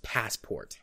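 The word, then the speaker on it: Passepartout: His name is actually pronounced Passport. – I wasn't sure. It was like, yeah. – Okay, is that supposed to be Passport? Because I know it's Jules Verne, but... – Yeah, no, his name is supposed to be pronounced Passport. It's French, but it pronounces, basically Passport. You can throw a little French influence on there if you want to, but...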